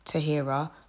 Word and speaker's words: Tahira: I think it's super easy to pronounce, Tahira. It's not that hard, but I've been called everything else under the sun. I've been called Tequila, Tahara, Sahara, Tahira, all sorts of different stuff.